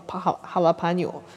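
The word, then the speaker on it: jalapeño: 哦，发这个发音我我还是很自信，叫 ha jalapeno，因为就是辣椒，墨西哥辣椒，因为真的很辣很辣。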